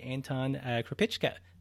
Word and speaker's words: Anton Krupicka: So let's welcome to the show today, Anton Krupicka.